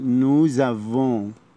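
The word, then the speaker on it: nous avons: لما يجي مع نو نحن نقول نوزافون، خلي بالنا انه ما كناش بننطق لسه، هننطقها وهننطقها زاد علشان جه بعديها في الكلمه الجديده اللي بعدها حرف فويل، حرف متحرك. نوزافون، نوزافون لسه لا تنطق في افون والان اتنطقت من الانف.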